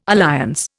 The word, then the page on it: alliance: Indian